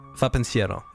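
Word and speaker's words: Va, pensiero: Yeah, it's called Va Pensiero.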